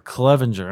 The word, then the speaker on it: Clevenger: Ryan, is it Clevenger? – Clevenger. – All right. Yep. Make sure I get that pronounced right.